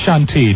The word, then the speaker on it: Chantilly: It's pronounced Chantilly, Don.